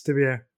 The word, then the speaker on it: Stibio: Yes, Stibio.